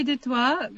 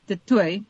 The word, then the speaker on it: du Toit: Yeah, so it used to be de Toit because it has that French connection, but here in South Africa, we made it de Toit.